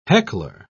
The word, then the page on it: heckler: British English